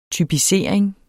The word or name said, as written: typisering